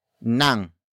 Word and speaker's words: ng: It is introduced by the complement phrase, starting with nang. Nang. Nang. Nang is spelled as ng.